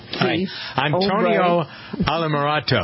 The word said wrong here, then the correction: Altamirano: I believe is that his name? – It's Altamirano.